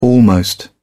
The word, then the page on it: almost: British English